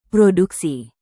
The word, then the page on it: produksi: indonesian